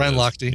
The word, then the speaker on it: Lochte: Ryan Lochte.